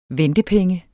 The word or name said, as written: ventepenge